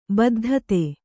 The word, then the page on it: ಬದ್ಧತೆ: baddhate